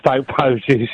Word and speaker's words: Stoke Poges: Hi, you pronounce it right. Some people call it Stoke Poges. – Is it Poges? – It is Stoke Poges, yeah.